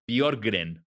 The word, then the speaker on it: Björkgren: Lo que sí sé es que ya busqué cómo se pronuncia su apellido. – Y se pronuncia Bjorgren.